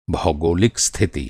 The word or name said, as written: भौगोलिक